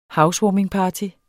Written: housewarmingparty

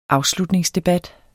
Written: AFSLUTNINGSDEBAT

